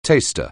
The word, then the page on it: taster: anglais